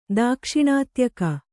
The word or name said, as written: ದಾಕ್ಷಿಣಾತ್ಯಕ